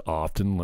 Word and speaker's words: often: And is the T really silent and often? Why was I beating myself up because I said it wrong when half the people say it wrong? Nobody says often, do they?